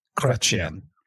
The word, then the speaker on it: Chrétien: Chretien.